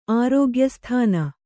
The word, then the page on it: ಆರೋಗ್ಯಸ್ಥಾನ: kannada